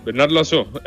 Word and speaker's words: Bernard Loiseau: Bernard Loiseau.